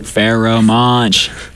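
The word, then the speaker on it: Pharoahe Monch: Pharaoh Monch.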